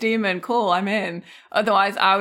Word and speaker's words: daemon: And there is a note in the beginning of the book that says demon, spelled D-A-E-M-O-N, and the little A and the E all smushed together, is pronounced like the word demon in English.